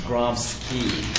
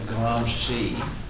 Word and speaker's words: Gramsci: If you're in Britain, it's pronounced Gramsci. That's how the Brits pronounce it. But in the United States, we say Gramsci.